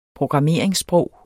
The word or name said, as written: programmeringssprog